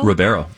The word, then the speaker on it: Ribeiro: Alfonso, how do you say his name? Ribeiro? – Ribeiro, yeah.